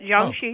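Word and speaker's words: Jiangshi (僵尸): And I'm probably going to pronounce the name wrong, but I believe it's Jiangshi, that's what they call it? – Yeah, you got it. Jiangshi.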